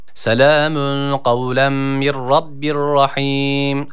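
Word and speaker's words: Kavlem (قولا): Kavlen kelimesine dikkat çekecektik. – Selamun kavlen. – Yani kavlen şeklinde okunuyor. – Selamun kavlem. Kavlem kaf. Yani kef harfi burada yok.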